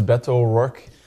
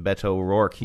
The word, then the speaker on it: Beto O'Rourke: Hey there, this is Beto O'Rourke. – Okay, so nobody got the memo. – And the O's blend together, almost like a Spanish pronunciation, Beto O'Rourke.